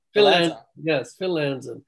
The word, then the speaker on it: Phil Lanzon: Phil Lanzon.